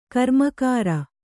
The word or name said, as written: ಕರ್ಮಕರ